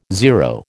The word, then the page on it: zero: American English